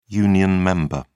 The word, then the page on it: union member: British English